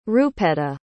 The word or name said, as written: rupetta